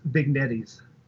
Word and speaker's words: vignettes: I think that's pronounced vignettes. Just so we're clear, I want to make sure we get that right.